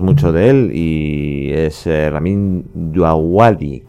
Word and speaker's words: Ramin Djawadi: Me tengo que ir a un traductor de internet para que me diga que se llama Ramin Djawadi.